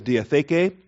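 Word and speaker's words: diatheke (διαθήκη): The Greek is diatheke, D-I-A-T-H-E-K-E, the long E's on the end of that, the aetis, diatheke, number 1242 in the Strong's Concordance, and only 33 uses.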